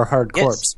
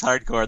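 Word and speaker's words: Hardcorps: I've changed my pronunciation, so I now say Hardcore since everyone says Hardcore. This is one of those like crazy things like that, apparently. – It's pronounced Core. – That's why I now say Core. – So I'm so confused. So is it Corpse? – It's Core. It's Hardcore. – Okay.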